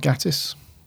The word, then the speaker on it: Gatiss: And it's, it's, uh, it's written by Mark Gatiss or Gattis. How do you say it? – Mark uh, Mark Gattis